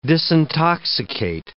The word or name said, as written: disintoxicate